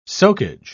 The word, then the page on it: socage: British English